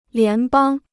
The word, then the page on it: 联邦: lián bāng